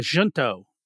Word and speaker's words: Junto: Yeah, Junto. – Junto. I've been calling it Huntis. – It's a J. I don't know if it's pronounced with a J or an H.